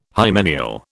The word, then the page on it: hymeneal: British English